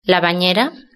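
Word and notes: la banera: Spanish (Spain)

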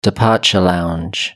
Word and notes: departure lounge: British English

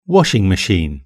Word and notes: washing machine: British English

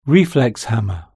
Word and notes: reflex hammer: British English

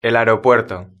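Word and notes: el aeropuerto: Spanish (Spain)

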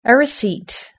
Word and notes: a receipt: British English

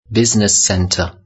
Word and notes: business centre: British English

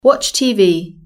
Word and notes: watch tv: British English

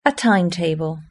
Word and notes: a timetable: British English

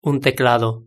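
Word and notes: un teclado: Spanish (Spain)

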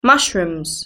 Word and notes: mushrooms: British English

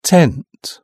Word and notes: tent: British English

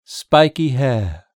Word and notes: spiky hair: British English

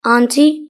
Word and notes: auntie: British English

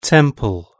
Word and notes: temple: British English

